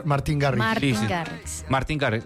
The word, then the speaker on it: Martin Garrix: Martin Garrix.